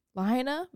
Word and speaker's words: Lahaina: Lahaina, Maui in Hawaii.